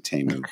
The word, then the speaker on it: Temu: But yes, my big takeaway is that, thank you, I've been pronouncing Temu right all along.